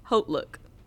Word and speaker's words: Haute Look: So, or Haute Look. Those are my two. – How you say it? – Haute Look. – That's the correct way to say it. – Haute. – It's not hot.